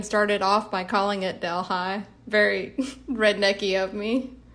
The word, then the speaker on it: Delhi: So the place where most of this book is set is a town in Louisiana that's spelled D-E-L-H-I, like the Indian city, Delhi. But how is it pronounced? – It's pronounced Delhi.